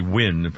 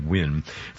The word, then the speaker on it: Nguyen: N-U-Y-G-E-N, pronounced in Vietnamese would be Nguyen, pronounced basically Nguyen.